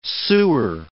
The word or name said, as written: sewer